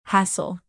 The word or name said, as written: hassle